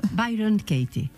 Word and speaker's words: Byron Katie: Doncs la Imma Zapata ens acompanyarà amb les seves meditacions i amb un projecte molt engrescador que va crear Byron Katie, és així com es pronuncia, Imma? – Sí.